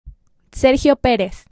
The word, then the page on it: Sergio Pérez: ˈseɾxjo ˈpeɾes